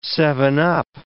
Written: seven-up

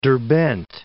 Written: Derbend

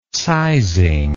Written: sizing

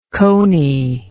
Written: cony

